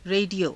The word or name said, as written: re_diyo